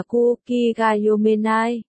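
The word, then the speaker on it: Kuki Gayomenai (空気読めない): Nhưng cũng có mấy cái cực kỳ cá biệt, công chi Gazumenai phát âm là Kuki Gayomenai,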